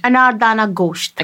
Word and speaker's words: Anardana gosht: Anardana gosht.